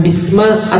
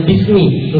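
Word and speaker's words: bismi (بِسْمِ): Kenapa bacanya bismi? Bukan bisma atau bismu atau bismallahi atau bismillahu gitu.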